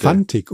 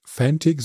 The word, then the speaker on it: Fantic: Fantic und vor allen Dingen wirklich F-A-N aussprechen und nicht Englisch Fantic, so wie ich das gerne mache.